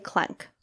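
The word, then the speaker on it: Klencke: It's K-L-E-N-C-K-E. – Okay. – So I would say Klenk.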